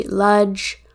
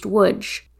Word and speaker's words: Łódź: In this episode, George and Cy pronounce the city Ludge, Luds, or Lods. My roommate from college is actually from that town in Poland, and they've told me it's pronounced Łódź.